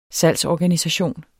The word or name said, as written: salgsorganisation